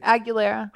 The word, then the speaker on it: Aguilera: Aguilera. – Christina Aguilera. – Aguilera? – Yeah. – The Christina Aguilera.